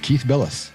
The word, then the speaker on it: Keith Bilous: Yes, Keith Bilous.